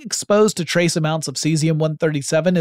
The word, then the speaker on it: cesium: This particular device contained within it a pellet of cesium-137, or if you prefer, kaizium-137, if we're going to pronounce Caesar as Kaiser as we should.